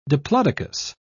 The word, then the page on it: diplodocus: British English